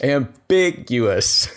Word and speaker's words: ambiguous: I think proficient is a very ambiguous. Is that right term? – Ambiguous? – Ambiguous. That's the term I was looking for. – You put the wrong emphasis on the wrong syllable.